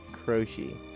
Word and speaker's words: Croce: Croce.